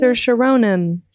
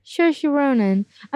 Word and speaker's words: Saoirse Ronan: It's Saoirse Ronan. – Saoirse Ronan.